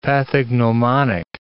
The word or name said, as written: pathognomonic